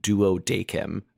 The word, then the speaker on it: Duodecim: Yeah, I am, for the record, still mad that 012 is supposed to be pronounced Duodecim.